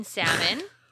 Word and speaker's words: salmon: So one that I saw was she pronounced the L in salmon.